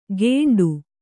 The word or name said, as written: ಗೇಂಡು